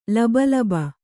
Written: ಲಬೋಲಬೋ

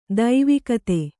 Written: ದೈವಿಕತೆ

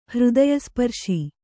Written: ಹೃದಯಸ್ಪರ್ಶಿ

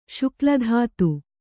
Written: ಶುಕ್ಲಧಾತು

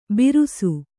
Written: ಬಿರುಸು

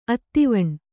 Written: ಅದ್ವಾನ

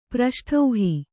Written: ಪ್ರಷ್ಠೌಹಿ

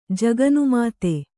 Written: ಜಗನುಮಾತೆ

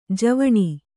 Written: ಜೀವಾಣು

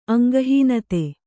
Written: ಅಂಗಹೀನತೆ

